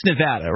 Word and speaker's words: Nevada: I've been corrected on this one before. It's Nevada, right? – Yes.